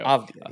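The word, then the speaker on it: Avdija: Yeah, Denny Advija, I don't even know how to, I might have totally butchered his last name there, but Avdia. He went 10, or Avdia, there you go. – Avdia, yes, sir.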